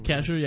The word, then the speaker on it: Kasher: Was it Moshe Kasher? Is that how you pronounce his name? – Moshe Kasher? – Kasher, yeah.